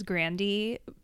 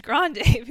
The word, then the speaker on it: Grande: And also she said, like, the actual pronunciation of her name is Grandy, but it's just Americans just assume it's Grande because Spanish is more popular in America.